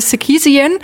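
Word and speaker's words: Sarkisian: ja alle, die Pop-Ikone Sherilyn, okay, da, da, kommen wir noch mit, aber Sekisian, das ist halt ein armenischer Name, ist, äh, ihr Vater war Armenier, ist nach Nordamerika eingewandert, deshalb spricht man das Ganze dann auch noch amerikanisch aus.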